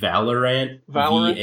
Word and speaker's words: Valorant: Valorant. – Valorant. – V-A-L-O-R-A-N-T.